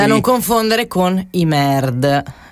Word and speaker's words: N.E.R.D: Sono i Nerd, scritto N-E-R-D, puntato, quindi un acronimo, però in realtà poi si legge Nerd, come i nerd, cioè quelli che poi smanettano sui computer.